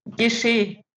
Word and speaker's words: Gesché: Also richtig wäre Valentin Gesche.